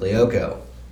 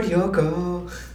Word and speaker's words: Lyoko: Or as the song, as you would hear in the theme song, because like, obviously, because it's spelled L-Y-O-K-O. – Okay. – But when you hear the theme song, it never hears like they pronounce the L, so it's like, Code Lyoko.